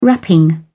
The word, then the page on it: wrapping: British English